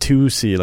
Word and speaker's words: 2C: So it's known to buyers as 2C, pronounced 2C, like the number 2 and the letter C.